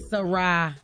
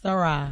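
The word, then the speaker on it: Sy'Rai: Now, when we come back, Soraya. Did I say that right? – Sarai. – Let me make sure. – Like, hey, Sarai, Sarai. It's just Sarai.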